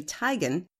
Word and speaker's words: Teigen: Chrissy Teigen is actually Tigan, and pop star Camila Cabello, who pronounces her name Cabello.